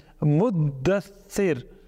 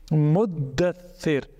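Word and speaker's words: Muddathir (مدثر): So it stated that where in Surah Al-Muddathir, remember this word, so people say Muddathir. It's not Muddathir, it's Muddathir. So the Dal itself is a Mushaddad, which is the correct way of pronouncing this. And it is a word from the glorious Quran too, so we must be able to pronounce it correctly. Muddathir. So the Dal itself is Tashdeed. The Tha is also Tashdeed.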